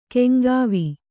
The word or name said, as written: ಕೆಂಗಾವಿ